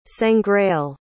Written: sangreal

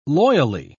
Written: loyally

